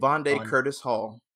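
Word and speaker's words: Vondie Curtis Hall: I just said his name completely wrong. Vonde, what is it? – Vonde Curtis Hall. – Oh, I said it right. Okay. Yeah, I like Vonde Curtis Hall.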